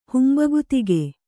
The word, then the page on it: ಹುಂಬಗುತಿಗೆ: humbagutige